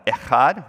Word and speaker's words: echad: For one, the Hebrew word that's translated here as one, echad, which Marty helped me pronounce perfectly. Was that good, Marty? Not too bad? I got to get more in the bowels, right?